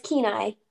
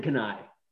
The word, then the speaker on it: Kenai: How do you say it? – It's Kenai. – Kenai. I said Kenai. – I know, that's still wrong. – That's still wrong. Okay. Okay. No partial credit.